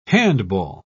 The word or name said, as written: handball